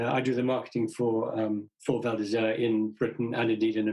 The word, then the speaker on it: Val d'Isère: I mean, I was checking out your website and I saw some places listed like the Val d'Isère in France.